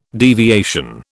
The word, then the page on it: deviation: British English